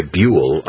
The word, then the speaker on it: Buhl: B-U-H-L, by the way, Buell. I only know how to say it because I used to live there.